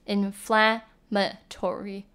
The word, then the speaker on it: inflammatory: 像是说 she did not take care of her wounds carefully like the doctor instructed and now she has an inflammation，她没有好好照顾她的伤口，所以她现在伤口已经发炎了。那 inflammatory 的发音呢，它的 MA 的 A 就发短音而 inflammatory，那可是如果讲到 inflammation 的话呢，重音是在 mation 这个地方，那 fla 是发短音鹅的音， inflammation 跟 inflammatory 重音不太一样。